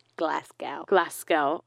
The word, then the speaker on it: Glasgow: Glasgow. – What is it? – Glasgow, yeah. No, you know, don't pronounce a W.